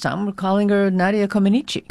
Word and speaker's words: Comăneci: Which apparently most of us have been pronouncing wrong for the past 50 years. – Oh, tell me what is the right way. – Well, no, you did it correctly. – Oh. – It's Nadia Comăneci.